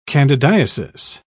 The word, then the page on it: candidiasis: British English